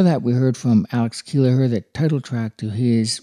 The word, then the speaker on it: Keleher: If you're looking up Alex's name, his last name is spelled K-E-L-E-H-E-R, Kelleher, easy.